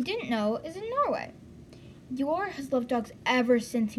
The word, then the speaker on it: Joar: When I saw this guy's name, it was spelled J-O-A-R, so I pronounced it Jor. Of course I did, but it is your.